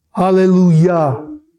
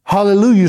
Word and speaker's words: Hallelujah: Tov, T-O-V, Le, L-E, Olam, O-L-A-W-M, and then the word Chesed, that H with a dot under it, E-S-E-D. Hallelujah is spelled the same way, that's the first word you'd say, but it's pronounced not hallelujah, the emphasis is not on the Lu, it's on the Yah, it's on the name of God.